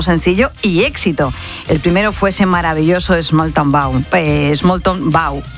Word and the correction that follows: Small Town Boy: Hoy voy, ahí se lo digo bien. – A ver. – Small Town Boy.